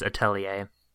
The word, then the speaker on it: Atelier: Oh, by the way, I googled the pronunciation, and it's Atelier.